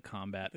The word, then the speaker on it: Kombat: I wish they really tried to emphasize the combat the pronunciation. K? Yeah. Combat.